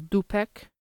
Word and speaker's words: dupek: The last one I have is Dupek, which is spelled D U P E K, and it means asshole.